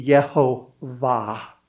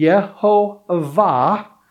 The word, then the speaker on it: Jehovah: So at least your grammar this morning, you're learning Hebrew and Greek this morning, we would look at Yahweh or Jehovah and the pronunciation of Jehovah, if we were staying legitimately to the letter and the T, it would be Yehovah, but they write Lord because they didn't want to take his name in vain.